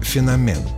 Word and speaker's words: феномен: В чем феномен слова «феномен»? Начнем с главного. В большинстве случаев правильно говорить «феномен» с ударением на второй слог.